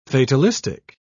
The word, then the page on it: fatalistic: British English